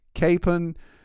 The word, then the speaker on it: Capon: It was Capon, all this discussion. And then, of course, just over the ridge, over the mountain from him was Kakapon. They had an extra syllable there.